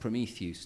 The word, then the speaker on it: Prometheus: About it's a translation of that poem, but reset in Northern England, and he says it's pronounced Prometheus to rhyme with Zeus because it ends EUS.